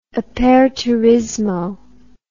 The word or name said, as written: aperturismo